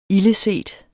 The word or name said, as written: ildeset